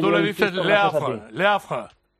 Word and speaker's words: Le Havre: Se dice Le Havre, porque la E final en francés no se pronuncia.